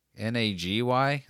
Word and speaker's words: Nagy: N A G Y? Is that how you would still pronounce that? – I think so.